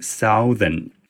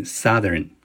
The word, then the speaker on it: southern: 我身边就有一个在挺不错的国际学校里面教英语的一个朋友，是一个本土人士，他居然读了三十年 Southern，经过我提醒之后，他才把它改正过来啊，读成 Southern。